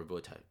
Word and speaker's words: Wojtek: It's pronounced Wojtek, though, or Wojtek.